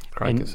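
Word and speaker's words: Kroeker: And then one of the directors on the show was Alan, I believe he pronounced it Kraker, K-R-O-E-K-E-R. I think he pronounces it Kraker, maybe Croker. I think it's Kraker. – Kraker sounds about right.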